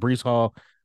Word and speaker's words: Breece Hall: My bench, Bryce Hall, George Pickens, Jameson Wilson. I did say that wrong. Breece Hall, George Pickens, fine.